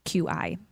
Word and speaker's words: Qi: Sorry, QI is how that's spelled. – Qi.